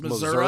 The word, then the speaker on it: Missouri: Can you pronounce that correctly, please? It's Missouri.